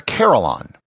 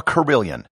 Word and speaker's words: carillon: On top of that, one of the instruments was a carillon, or as it's pronounced in England, a carillon.